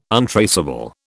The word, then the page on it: untraceable: British English